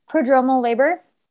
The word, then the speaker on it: prodromal: So for 2 weeks, I was in prodromal labor. Am I saying that correctly? – Prodromal labor.